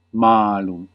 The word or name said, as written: fuck